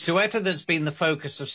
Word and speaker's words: Ceuta: Of it's Ceuta, isn't it? Is that right? – It's Ceuta. Ceuta. It's spelled C-E-U-T-A, but it's pronounced Ceuta in Spanish.